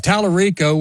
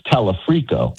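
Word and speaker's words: Talarico: If you and I sit here on November the 4th and 5th and say, okay, we got Dr. Abdul, we have Tallarico, we have Angie Nixon, do you and I not perceive reality? Is that possible? – I think you're mispronouncing his name. It's Tallarico.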